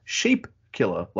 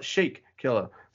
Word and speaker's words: Sheep Killer: Apparently it is sheep killer, like sheep, not sheik killer. So we've been hearing it wrong and saying it wrong.